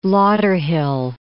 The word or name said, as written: Lauderhill